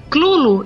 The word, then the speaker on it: Cthulhu: Porque ninguém sabe exatamente qual é a pronúncia. Porque Lovecraft diz que é um nome impronunciável. O mais próximo é Clulo e não Cthulhu.